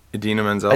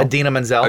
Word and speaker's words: Idina Menzel: Idina Menzel? – Idina Menzel.